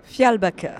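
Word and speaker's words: Fjällbacka: Fjallbaka.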